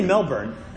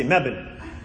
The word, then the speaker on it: Melbourne: That's how they say it there. They don't say Melbourne. They say Melbourne.